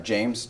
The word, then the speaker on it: James: James, J-A-M-E-S, last name Zaborvan, Z-B.